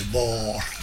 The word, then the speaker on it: bor: Una volta viene Pellegrini, il professor Pellegrini, a casa nostra, e stava bevendo un bicchiere, io gli ho detto la frase e mi ha corretto immediatamente la pronuncia di Bor. – Urca. – No, si dice Bor.